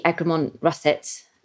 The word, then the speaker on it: Egremont Russet: And I was struck by this when I was actually, you sent me one of your ciders to try, which is the Egremont Russet.